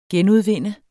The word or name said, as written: genudvinde